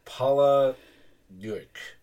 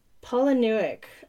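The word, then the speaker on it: Palahniuk: Paula Newick. – Paula Newick.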